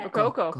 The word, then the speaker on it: Rococo: Do I say too many cocos in it? – Rococo. No, you don't.